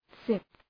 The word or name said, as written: sip